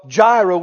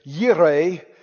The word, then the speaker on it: Jireh: Actually, you know, we get the compound name Jehovah Jireh, we say, or I guess maybe a better pronunciation is Yireh, but it doesn't work good in the song.